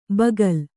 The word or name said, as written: ಬಗಲು